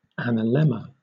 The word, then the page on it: analemma: British English